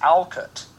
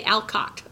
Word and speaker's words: Alcott: Sarah and I saw a very interesting documentary on Alcott recently, and by the way, found out in that it's pronounced Alcott.